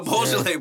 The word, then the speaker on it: Beaujolais: Even if you just say Beaujolais. – Yeah, I'm saying Beaujolais. I'm about to start... Come on. That's what I'm saying, Beaujolais? – Beaujolais boys.